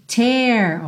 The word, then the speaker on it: tear: Atenção à pronúncia desse verbo tear, ok? Como se fosse um E com acento agudo e o T que tem aquele t, t, arzinho saindo, ok?